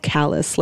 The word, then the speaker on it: Calais: It's called Calais. It's spelled Calais, but they say it like the thing on the bottom of your foot.